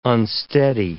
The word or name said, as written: unsteady